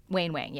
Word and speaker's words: Wayne Wang: And I'm going to cut this part out, but it is I'm pronouncing his name right. – Wayne Wang, yeah.